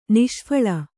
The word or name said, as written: ನಿಷ್ಫಳ